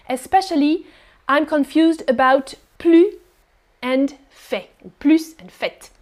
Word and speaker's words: plus: What I can say, what is the easiest thing to say is that in negative sentences, you don't pronounce the S, the final S. Plus.